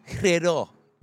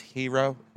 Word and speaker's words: gyro: It's gyro. – It's with a silent G. – Yeah. I hate when people correct you. Like, gyro. They're like, it's hero. Relax, okay?